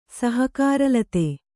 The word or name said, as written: ಸಹಕಾರಲತೆ